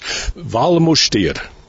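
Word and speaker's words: Val Müstair: We are in a place which in English is called the Valley of the Minster Valley, or locally, and I'll try to get my pronunciation right, because here they speak Romansh, Switzerland's fourth national language, Valmustir. Is that correct, Gabriella Binkert? – Yes, Bob, that's correct.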